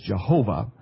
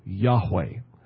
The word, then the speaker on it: Yahweh: Now, sometimes the Old Testament in the King James sometimes uses a word that we pronounce as Jehovah. But if you look at the actual Hebrew text, a more accurate pronunciation of that, which is a letter for letter and sound for sound, would be Yahweh.